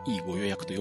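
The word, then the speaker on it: e5489: アルファベットの E に数値で 5489 と書いて E ご予約と読むんですけど、えー、この E ご予約で購入できるネット予約専用の、えー、っと、片道切符。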